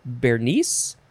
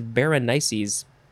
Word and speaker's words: Berenices: I mean, I would say Bernice, but the pronunciation guy says Berenices.